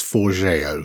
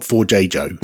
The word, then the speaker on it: Forgejo: I think it's Forgeo. – Uh-huh. – I think. It's not Forgejo, which I think is how it looks on page to us silly Brits.